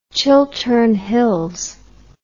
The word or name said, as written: Chiltern Hills